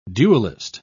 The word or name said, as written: duelist